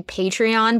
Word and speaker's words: Patreon: I say Patreon, but also I know that it's actually Patreon, but for some reason it's like GIF versus GIF in my mind, and I just can't break out of it.